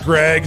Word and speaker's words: Graig: Yeah, it's just Greg. It's spelled G-R-A-I-G, so I don't get any cool stuff.